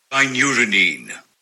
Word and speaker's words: kynurenine: Kynurenine... No, that sounded like urine. Kynurenine... Oh, God, where's Google? Here's Google. – Kynurenine.